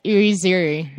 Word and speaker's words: Irizarry: Oh, yeah, it's Irizarry. – Yeah. Irizarry. – Yeah, I try to do like a phonetic version on Twitter, but uh, ah. I uh, put like three different pronunciations, so now everyone's like, "Hey, Natasha."